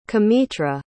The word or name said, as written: cametra